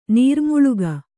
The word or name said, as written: ನೀರ್ಮುಳುಗು